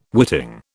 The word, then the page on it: witting: British English